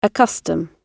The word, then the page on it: accustom: British English